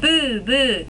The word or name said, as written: bubu